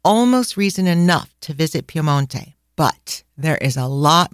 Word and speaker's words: Piemonte: Allora, we want to share with you why you should visit the region of Piemonte, which all of you know is Piedmont in English, but Piemonte, we're sticking to the Italian version.